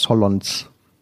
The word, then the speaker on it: Solondz: Spricht man, glaube ich, so aus. Solondz.